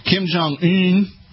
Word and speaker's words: Kim Jong-un: Evidently, according to the Daily Mail out of London, Kim Jong-un, that's the technical pronunciation of his name,